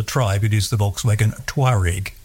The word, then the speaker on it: Touareg: but everyone at Volkswagen, and indeed virtually everyone everywhere, pronounces Touareg. So they lose one whole syllable in there. So it's the Volkswagen Touareg.